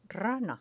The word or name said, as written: rana